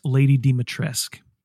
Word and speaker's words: Dimitrescu: Sean, that's Lady Dimitrescu. That is the pronunciation.